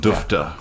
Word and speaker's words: DUFTA: DUFTA. For sure. – Duluth Foot Trails Alliance. – Rhymes with UFTA.